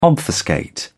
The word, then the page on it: obfuscate: British English